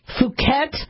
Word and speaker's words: Phuket: and the Phuket, not short U, let's use the long U, Phuket lineage B Yamagata lineage of the 2013-like virus.